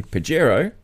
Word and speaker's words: Pajero: Because apparently Pajero, pronounced Pajero in Spanish, is penis.